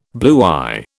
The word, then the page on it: bluey: British English